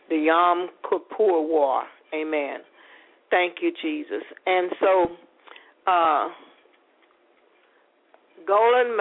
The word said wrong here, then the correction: Golda: Meir, Golda, I want to pronounce it right, Meir called for Nixon in the middle of the night and begged him to help her country.